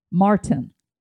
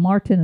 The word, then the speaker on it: Maurten: Hammer, Noon, and that's spelled N-U-U-N. Salt Stick, Scratch, that's S-K-R-A-T-C-H. Tailwind, T-A-I-L-W-I-N-D. Ultima, and Martin. And Martin is spelled M-A-U-R-T-E-N.